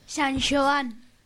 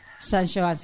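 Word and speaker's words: San Xoán: ¿Alguien sabe cómo se dice San Juan en gallego? – Ni idea. – No. – San Joan. – San Joan, se te ha adelantado.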